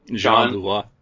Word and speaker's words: Jean Beauvoir: Juan Beauvoir, I think that's how you pronounce his name. Jean Beauvoir. Yeah, yeah.